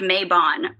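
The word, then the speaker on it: Mabon: I think it's actually, I listened to a podcast today and the dude was like, it's pronounced Maybon. Please stop.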